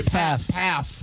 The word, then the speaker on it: Pfaff: Pfaff. – P-F-A-F-F. – Yes, first F silent.